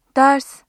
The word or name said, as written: ders